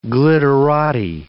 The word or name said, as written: glitterati